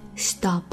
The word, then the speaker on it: stopped: When the clock stopped stop 重读闭音节，双写了 P 然后呢加上 ED，轻辅音结尾的动词加了 ED 之后 ED 的发音是 T stopped。